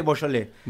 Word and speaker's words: Beaujolais: Se escribe Beaujolais. Beaujolais se dice Beaujolais.